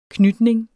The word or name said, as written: knytning